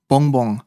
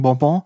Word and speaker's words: Bonbon: Das ist nicht einfach für mich auszusprechen, weil ich will es einmal auf Französisch aussprechen. Bonbon. Auf Schweizerdeutsch, Daffeli, Sugus, Zartli. Je nach Region. Bonbon. Bonbon. Also wie eigentlich die Bon, aber einfach zweimal.